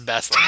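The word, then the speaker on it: Bas-Lag: It's called, he pronounces it Barlag, but it's spelled Baslag. Do you know? I guess it's Baslag. Is that the trilogy? Anyone? Bueller? – I would say use Martin rules. However you want to pronounce it is correct. – I'm pretty sure it's Baslag. I'm pretty sure it's... – Well, the narrator says Baslag.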